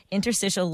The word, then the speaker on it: interstitial: Am I saying that right? I know you tried helping me out with how to pronounce this word. Because when people get bad news and they hear something new, how do I pronounce this? – It's Interstitial lung disease.